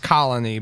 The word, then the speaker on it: Colonie: I hope it's pronounced colony, but it's not spelled like colony. – Is it C-O-L-O-N-I-E? – Yeah.